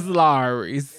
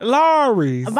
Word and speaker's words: Lawry's: Larry's? – Is Lowry's. – It is. He is saying it correctly.